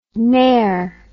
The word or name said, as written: nair